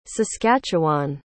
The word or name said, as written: saskatchewan